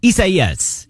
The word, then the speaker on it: Isaias: Hurricane Isaias, I think, is how you properly pronounce that. Let's check with Metro News meteorologist Ryan Hughes. Am I saying it right? Close enough? – Yes. Yeah, close enough. Isaias. Yes. All right. That is correct. Isaias. Yeah.